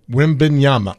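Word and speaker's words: Wembanyama: So Wimbenyama. – Yeah, that's good. – Wimbenyama.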